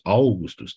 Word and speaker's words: Augustus: Noi zicem Augustus. Augustus trebuie pronunțat într-o răsuflare, că e diftong.